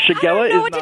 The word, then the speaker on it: Shigella: Shigella? Am I saying it right? – Shigella, yes. – S-H-I-G-E-L-L-A?